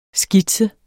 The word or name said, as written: skitse